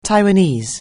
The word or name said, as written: Taiwanese